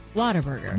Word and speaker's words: Whataburger: They did it phonetically, and they did W-A-T. Although you're right, it is spelled Whataburger, but it's Whataburger.